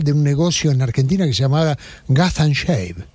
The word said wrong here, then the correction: Gatti Chávez: Y dije, sí, tonto, es Gatti Chávez. – Claro, porque es Gatti Chávez.